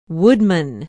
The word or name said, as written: woodman